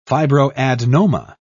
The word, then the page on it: fibroadenoma: British English